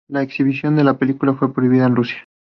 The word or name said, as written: prohibida